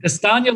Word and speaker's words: Castagna: Castagna, lasagna.